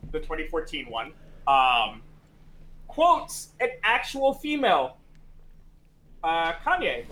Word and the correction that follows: Kayane: right? Uh, K- Kane, how do you say her name? – Kayane. So, Kayane.